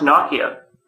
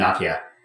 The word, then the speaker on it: Nokia: Apparently pronounced Nokia. – Yeah, it's definitely Nokia. – Is it really? – Yeah, well, in Finnish, yeah.